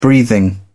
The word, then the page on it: breathing: British English